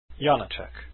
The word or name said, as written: Janacek